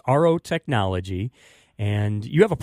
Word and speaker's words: Aro: Is it Aro? A-H-R-O is how it sounds, but I know it's A-R-O. – It is Aro.